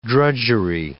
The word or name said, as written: drudgery